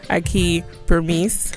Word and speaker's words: Akie Bermiss: And today we are joined by the wonderfully talented Akie Bermiss.